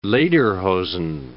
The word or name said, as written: lederhosen